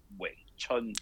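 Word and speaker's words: Wing Chun: So Grandmaster Yip Man, for some odd reason, thought that V was a W sound because it's pronounced Wei Chun in Cantonese.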